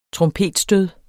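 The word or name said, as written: trompetstød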